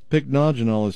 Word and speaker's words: pycnogenol: Pycnogenol is spelled P-Y-C-N-O-G-E-N-O-L, pycnogenol.